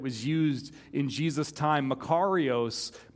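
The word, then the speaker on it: Makarios (μακάριος): It does mean blessed, but it's the Greek word makorii or makorios. Everybody say that, makario. I said that wrong. I had to like look up how to pronounce it this morning. Makarios is how you say it. Everybody say that out loud, makarios.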